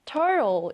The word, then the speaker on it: turtle: T-I-G-E-R, tiger, 호랑이입니다. 자, 다음 단어는 turtle, 거북이인데요. 자, 같은 T이긴 하지만요. 우리가 강세가 들어가지 않는 T는 약하게 발음을 할 때가 있어요. 그래서 터틀도 괜찮지만 turtle 이렇게 발음을 해준 것이죠. 자, 써볼까요? turtle, T-U-R, 그리고 T-L-E.